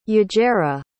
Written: yajaira

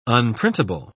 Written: unprintable

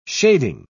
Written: shading

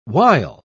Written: wile

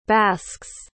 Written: basques

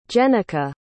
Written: jenica

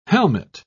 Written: helmet

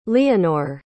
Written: leonor

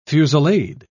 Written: fusillade

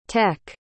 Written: tech

